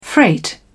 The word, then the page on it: freight: British English